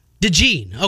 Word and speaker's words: DeJean: So Cooper DeJean, how are we pronouncing this thing first of all? – DeJean. – Okay, DeJean. Easy enough. Just like it looks, dude's a stud.